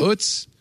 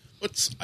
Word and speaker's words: Utz: As much as I like the name Utz as a product name. – Is that how you pronounce it? Yeah. Utz. – Utz.